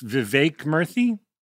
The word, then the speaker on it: Vivek Murthy: It's Vivek Murthy.